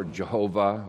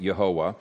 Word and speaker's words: Yehovah: Anytime Lord is in all capital letters, it's the name Yehovah or Jehovah or Yahweh, however you want to pronounce that. I pronounce it Yehovah.